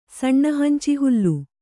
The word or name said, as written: ಸಣ್ಣಹಂಚಿಹುಲ್ಲು